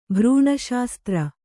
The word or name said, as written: ಭ್ರೂಣಶಾಸ್ತ್ರ